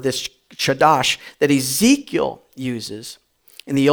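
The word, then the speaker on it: chadash (חדש): It's chadash. It's a chadash. If you spit in the person's back of the neck, in the person in front of you, you've said it correctly.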